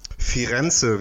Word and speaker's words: Firenze: Firenze, wie der Italiener sagt, ne? Yes. Oh, ja, genau. Betonung auf dem, auf diesem glatten R.